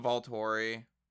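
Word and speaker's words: Volturi: She was like one of the Voltori, if I'm saying that right.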